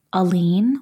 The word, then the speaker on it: Alene: I usually say a lean, like one single lean back, like a oh, lean versus a lean.